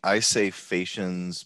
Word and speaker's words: Phaeacians: I say Phaeacians, but I think that's something more like the received British school pronunciation. – The English pronunciation of the Latin version of the Greek word or something.